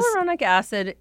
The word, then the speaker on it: hyaluronic acid: I want to know in particular about hyaluronic acid. – Am I pronouncing that correctly? Yeah. Because hyaluronic acid is, it's certainly a great ingredient.